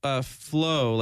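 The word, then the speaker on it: Flo: Flow, like Flow, Florida. – I don't know. – Yeah. – Point. It's F-L-O, Grown.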